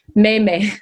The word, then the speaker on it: meme: but they do say meme, but they pronounce it precisely how it would be pronounced in Spanish. So it's meme.